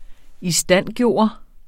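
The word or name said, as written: ISTANDGØRE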